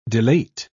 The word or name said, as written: delate